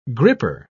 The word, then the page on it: gripper: British English